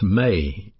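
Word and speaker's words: me (μή): Along with the word judge, we have a Greek particle pronounced me, M-E.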